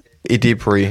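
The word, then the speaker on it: Edebiri: Und das ist Ayo Ebori. Wie wird sie ausgesprochen? – Ayo Edebri? – Edebri oder so? Edebri, ja, genau.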